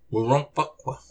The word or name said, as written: Wurôkpuq